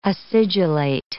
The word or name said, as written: acidulate